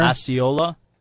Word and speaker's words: Osceola: Osceola.